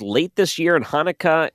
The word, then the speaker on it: Hanukkah: Pronounce this word here, but Happy Chinooka. – Happy Chinooka. It's an Hanukkah.